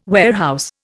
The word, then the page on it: warehouse: Indian